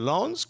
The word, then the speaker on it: Lens: And I believe it's Lons. – That Jersey French accent that we all love. – Is it Lons? Because there's no e after the s, so I don't think you pronounce the s.